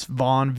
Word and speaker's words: Von: It's Vaughn, V-O-N.